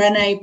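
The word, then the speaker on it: Brené: Renee Brown.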